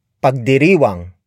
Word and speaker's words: pagdiriwang: This lesson is brought to you by Tagalog word pagdiriwang.